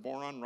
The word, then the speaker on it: Boron: Have you ever even heard of Boron? Rhymes with moron.